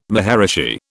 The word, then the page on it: maharishi: British English